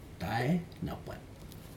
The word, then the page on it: tai napat: Aneityum